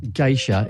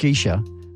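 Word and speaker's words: Gesha: So, well, firstly, geisha is incorrect. They're actually called geisha. Okay, so it's a mispronunciation, misspelt.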